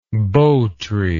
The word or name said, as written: bo tree